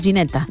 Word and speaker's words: Ginetta: En italiano se dice igual. – Sí, decimos Ginetta. – Ginetta. Oh, qué nombre más bonito, ¿eh? – Es muy bonito. – Sí, sí. – Es como gelato, pero Ginetta.